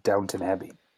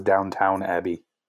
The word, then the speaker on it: Downton Abbey: I thought it was Downtown Abbey. – And they just mispronounced it? – I guess I mispronounced it.